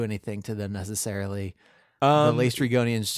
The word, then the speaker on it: Lestrygonians: The Lestrygians, or whatever they're called, Lestrygonians says the Odyssey pronunciation guide that we got from an elementary school website. – Okay, Lestrygonians.